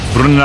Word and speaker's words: Brunner: Brunner Alemania. – Ahí no tiene problema el Sala en pronunciar, Bruno.